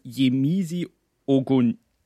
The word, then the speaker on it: Yemisi Ogunleye: aber immerhin eine Silbermedaille beim Kugelstoßen gab's von Yemisi Ogunleye, wird die so ausgesprochen? – Ja, genau. Denk schon.